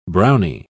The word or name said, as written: brownie